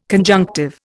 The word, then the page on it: conjunctive: Indian